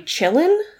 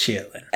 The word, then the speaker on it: qilin: Now, this thing is called a chillin. – Yeah, because it's chillin. – But it's spelled Q-I-L-I-N. – Oh, okay. – Because it's Chinese.